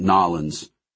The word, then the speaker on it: New Orleans: "You sound like you're from New Orleans," Debbie told her, doing her best to pronounce it Nollins.